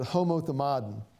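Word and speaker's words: homothumaden (ὁμοθυμαδόν): You see, the word accord in Greek, we would pronounce it homothumaden. Homothumaden, which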